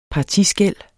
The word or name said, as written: partiskel